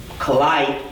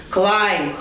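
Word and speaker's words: climb: Правильно произносим. Клайм, да? Ай, должен быть звук. Клайм, хорошо.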